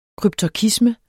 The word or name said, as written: kryptorkisme